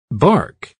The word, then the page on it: bark: American English